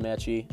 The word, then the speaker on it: Maedche: That's Austin Maedche, Maedche. Yes, the D is silent.